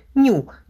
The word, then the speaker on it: ню: Если идти строго по алфавиту, этот неприятный вариант с большим количеством мутаций должны были назвать другой греческой буквой – «ню», но решили, что по-английски возникнет путаница, потому что произносится это так же, как слово «новый».